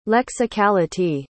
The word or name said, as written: lexicality